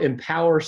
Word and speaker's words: EMPWR: It's called Empower Solar. It's spelled kind of funny though. It's spelled E M P W R.